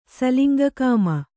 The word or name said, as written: ಸಲಿಂಗಕಾಮಿ